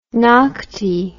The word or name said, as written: nocte